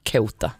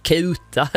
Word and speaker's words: Ceuta: Så att det blev Ceuta. Lite som Ceuta, alltså i och med att man kutar i Skåne typ.